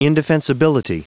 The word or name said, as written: indefensibility